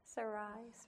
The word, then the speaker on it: Sarai: So we actually meet Sarah, and actually as Sarai or Sarah, or how did you say her name? – Sarai, I'll just say it that way.